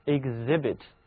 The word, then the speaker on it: exhibit: 字母 H 在 exhibit 这个单词里呢是不发音的，而 H 前面的字母 X 则发了两个音 gz exhibit gz，它同时跨越了这个单词重音的前后两个部分 exhibit。